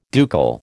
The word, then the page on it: ducal: American English